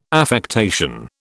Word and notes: affectation: British English

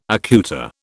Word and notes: accoutre: British English

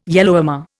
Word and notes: Yellowhammer: Indian

